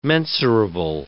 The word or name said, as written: mensurable